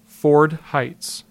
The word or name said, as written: Ford Heights